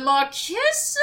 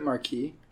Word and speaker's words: Marquess: Can we take 1 second before you continue on just to have a go at going, "The Marquess of Queensberry"? – Isn't it Marquis? – Wait. – The Marquis of Queensberry. – No. It's not. Yeah, it's Marquess because it's spelled Marquess, not Q-I-S.